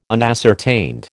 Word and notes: unascertained: American English

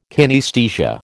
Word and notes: kinaesthesia: American English

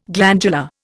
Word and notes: glandular: Indian

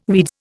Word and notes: weeds: Indian

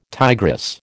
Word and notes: tigress: American English

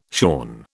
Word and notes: shorn: British English

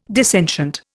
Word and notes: dissentient: Indian

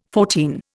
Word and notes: fourteen: Indian

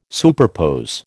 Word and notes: superpose: American English

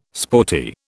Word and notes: sporty: British English

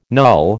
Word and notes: null: American English

